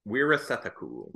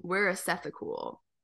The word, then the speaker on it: Weerasethakul: Apichatpong Weerasethakul. – Weerasethakul.